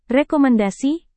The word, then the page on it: rekomendasi: indonesian